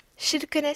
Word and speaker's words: Kirkenes: Kirkenes, si on le prononce à la façon locale.